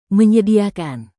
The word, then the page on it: menyediakan: Indonesian